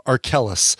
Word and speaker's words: Arkells: A brand new album from an artist by the name of Arkellis, not to be confused with R. Kelly. Arkellis is spelled Arkells, Arkells.